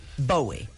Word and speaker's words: Bowie: What's the proper pronunciation? – I think David would let you pronounce it any way you wanted him. – But it's actually Bowie. – In England, that's what they call him.